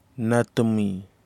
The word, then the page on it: natimi: Kwamera